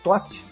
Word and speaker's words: Thoth: O livro pode ser adquirido direto do site da editora, a editora TOT, que se escreve T-H-O-T-H, que é de Londrina, no Paraná, ou então pela internet mesmo, se acha fácil também, em livrarias, né?